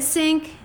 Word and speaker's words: Cink: It's at Lorraine Cink everywhere. Cink is spelled with a C. Don't be deceived. It is said sink. It has a C. Constant confusion for people.